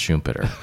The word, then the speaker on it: Schumpeter: Well, first, it's pronounced Schumpeter.